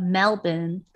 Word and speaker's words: Melbourne: The Real Housewives of Melbourne, because that's how they say it there, Australia, is now on Discovery Plus.